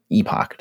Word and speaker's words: epoch: We should call the epoch or epoch, depending on how you want to pronounce this word, E-P-O-C-H.